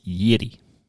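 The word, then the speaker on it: Jiří: The correct pronunciation I got scolded is Yiri.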